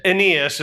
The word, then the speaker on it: Aeneas: I prepped him, but you know, Aeneas is how it's pronounced, or at least in my study of that, that's where I came to.